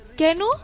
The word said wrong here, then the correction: Keanu: no, és crec, Ke, o sigui, Keanu? crec que seria Keanu Reeves